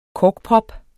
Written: korkprop

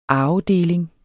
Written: arvedeling

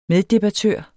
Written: meddebattør

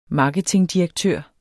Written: marketingdirektør

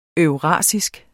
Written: eurasisk

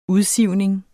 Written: udsivning

